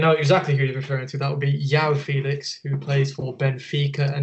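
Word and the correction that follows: João: I think it's pronounced Zhao Felix.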